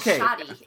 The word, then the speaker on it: shawty: Like, shoddy is spelled S-H-A-W-T-Y.